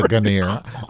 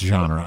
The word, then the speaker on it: genre: Like, it will be a Ganir. I know it's pronounced genre. I'm not stupid, but I like Ganir.